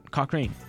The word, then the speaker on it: Cochrane: Cochrane. It's spelled C-O-C-H-R-A-N-E, Cochrane, to Detroit for $100,000.